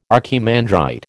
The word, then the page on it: archimandrite: American English